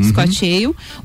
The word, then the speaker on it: Scotch Ale: Scotch Ale, é essa a pronúncia, Scotch né? Ale. Uhum. Scotch Ale.